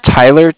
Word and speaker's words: Tyler: My name's Tyler, T-Y-L-E-R, and I'm calling with Cardiff. It's spelled C-A-R-D-I-F-F.